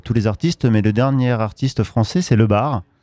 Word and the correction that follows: Le Bard: Le Bard, pardon. – Ah, le D se prononce.